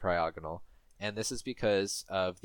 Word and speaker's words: Cryogonal: Am I pronouncing, is the emphasis in the correct place now? – What I would say. – You need to know these things now. – Cryogonal. – Cryogonal, okay.